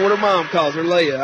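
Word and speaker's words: Leia: It's spelled L-E-I-A. I would call that Leah, but no, it's Leah. You know, you get to pronounce it the way you want to, and it's Leah.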